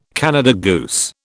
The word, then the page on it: Canada goose: British English